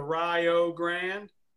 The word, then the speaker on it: Rio Grande: But awesome, man, starting a program out there at Rio Grande, and he pronounces it the Rio Grande for all you folks out there that need help with that. – Think Rio like Ohio.